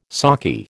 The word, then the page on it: sockeye: American English